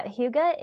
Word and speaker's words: hygge: Yeah, hygge is a Danish word, and I'll spell it for your listeners because it doesn't sound like the way it's spelled. It's H-Y-G-G-E.